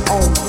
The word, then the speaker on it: Om: The word that is used in vocal meditation is Om. It's actually pronounced A-O-U-M. Think of it as A-O-U-M.